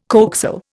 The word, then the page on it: coaxial: Indian